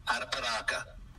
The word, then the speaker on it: Arapiraca: Arapiraca.